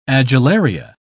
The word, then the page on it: adularia: British English